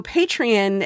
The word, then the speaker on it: Patreon: And so Patreon, it's spelled P-A-T-R-E-O-N. So it's like the word patron, but with an E in the middle.